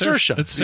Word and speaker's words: Saoirse: No, it's Saoirse. You pronounce it like inertia, and she told us how to pronounce it like inertia on SNL when she was the host.